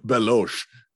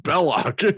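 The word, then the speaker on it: Belloq: Belosh. Belloc. It's pronounced Belloc.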